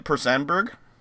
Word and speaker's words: Per Sandberg: Is that how you say it? Per Sandberg? – Yes.